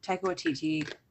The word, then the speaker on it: Taika Waititi: Yeah, I started watching that because I'm a big Taika Waititi fan.